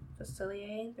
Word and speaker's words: Facilier: Mine is either Captain Hook Thinking a Happy Thought or my new Dr. Facilier, the Remarkable Gentleman.